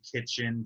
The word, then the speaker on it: KTCHN: Order from Kitchen Downtown LA. It's spelled K-T-C-H-N. So Kitchen with none of the vowels in it.